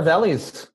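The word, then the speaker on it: Vellies: He said Brother Versailles. – What is it? – It is Brother Valley's. – Oh, Vales? My bad. – Other valleys. It's spelled like jellies with a V.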